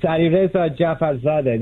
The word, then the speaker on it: Alireza Jafarzadeh: With us today is Alireza Jafarzadeh. Alireza, did I say it right? – Perfect. It's Alireza Jafarzadeh.